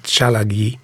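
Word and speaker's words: Tsalagi: What we really say in the show is that we sort of point out that it's odd that the Cherokee people can't say the word Cherokee in their language. They'd have to pronounce it Chalagi.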